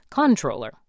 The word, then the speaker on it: comptroller: On paper, the word begins with the letters C-O-M-P-T. And according to my unscientific survey, most New Yorkers pronounce all of them. – How do you pronounce it? – Comptroller. – Yeah, like it's spelled.